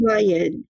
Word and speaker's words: Kamayan: Kamayan.